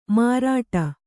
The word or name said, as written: ಮಾರಾಟ